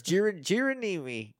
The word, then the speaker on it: Geronimi: Clyde Geronimo. – It's not Geronimo. It's Geronimi.